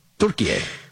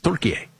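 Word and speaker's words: Türkiye: It's the Turkish pronunciation of Turkey. Turkiye. – I'm a child. That was a good one. – Yeah. It's Turkiye.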